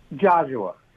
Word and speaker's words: Geozuwa: I want to make sure I'm pronouncing your name correctly. Is it Geozuwa? – Geozuwa.